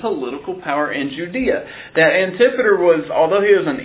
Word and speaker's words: Antipater: It begins with Antipater, or Antipater. I've heard it pronounced both ways. I'll probably use Antipater.